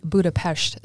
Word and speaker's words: Budapest: So I have visited Budapest, but it's actually Budapest is how you say that.